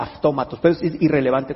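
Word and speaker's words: aftómatos (αὐτόματος): Nunca se las he dicho, pero es la palabra aftómatos. Aftómatos es de donde viene automático. Aftómatos, incluso si la del griego la transliteras al castellano, casi se escribe automatos, pero se pronuncia aftómatos.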